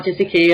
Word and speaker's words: Gesicki: Mike Gesicki, yeah.